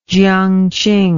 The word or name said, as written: Jiang Qing